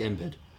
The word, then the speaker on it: Embiid: I like Embiid. – Embiid. – Zion. – There's two I's in there, Bob. – One is silent.